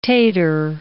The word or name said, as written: tater